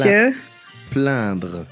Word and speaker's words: se plaindre: Pla- Se. – Plaindre.